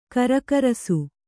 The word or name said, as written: ಘರ್ಘರಿಸು